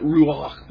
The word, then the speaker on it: ruach: You've got to say it like that, ruach, with a little phlegm in the back of your throat.